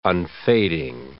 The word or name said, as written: unfading